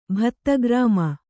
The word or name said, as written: ಭತ್ತಗ್ರಾಮ